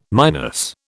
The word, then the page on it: minus: British English